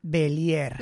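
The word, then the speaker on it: Bélier: Eh, la familia Berlier. – La familia Belier. – Bueno, ¿cómo se dice? – Correcto, sí, bueno, Belier, Berlier, Belier.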